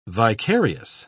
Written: vicarious

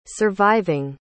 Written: surviving

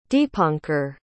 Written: deepanker

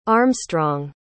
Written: armstrong

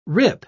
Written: rip

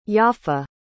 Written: yafa

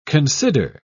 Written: consider

